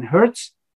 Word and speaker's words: Hertz: Es war der ehemalige Oberrabbiner des britischen Weltreichs, Josef, Josef Hermann Herz, wird man ihn auf Deutsch aussprechen, äh, auf Englisch wahrscheinlich Joseph Hermann Hertz.